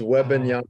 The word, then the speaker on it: Wembanyama: I think they're gunning for that Victor Wantanabe guy from France. Uh, I don't know if I'm pronouncing that name right. That's where you come in. – It's Wembanyama.